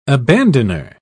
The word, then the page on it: abandoner: British English